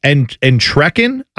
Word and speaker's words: Entrekin: Rachel Entrekin, I believe is how you say her last name, E-N-T-R-E-K-I-N.